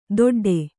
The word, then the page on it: ದೊಡ್ಡೆ: doḍḍe